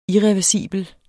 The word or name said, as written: IRREVERSIBLE